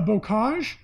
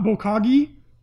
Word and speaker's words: Bocage: They're Bocage, and not Bokagi, like Pablo thought.